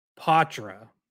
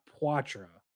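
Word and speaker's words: Poitras: Although, actually, I should correct myself, because Steve Conroy asked Poitra today how you pronounce his name, and he said Poitra, not Poitra. So, like, good, okay. – French-ish, but not the full French.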